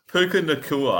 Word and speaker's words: Puka Nacua: I think it's Nuka Puka. I don't know. I couldn't even tell you how to say it. – Puka Nacua.